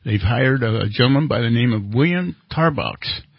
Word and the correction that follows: Tarbox: Tarbo. It's pronounced Tarbo. – Tarbo. Tarbo. Okay. – Like Tarbo. – Very French. – Silence. – Oh, very French.